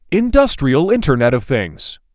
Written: Industrial Internet of Things

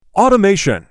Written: automation

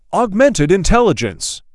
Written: Augmented intelligence

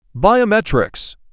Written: Biometrics

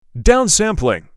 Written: Downsampling

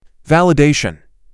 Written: Validation

